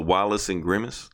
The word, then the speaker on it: Wallace and Gromit: You said that was the Wallace and Gromit.